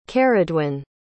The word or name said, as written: ceridwen